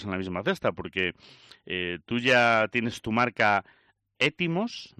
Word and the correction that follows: Éftimos: ¿no? Éftimos, ¿se escribe? – Éftimos, eso es, justo.